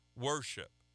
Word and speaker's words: worship: W-O-R-T-H-S-H-I-P. And it, when we pronounce it now, we don't say worth-ship, we say worship.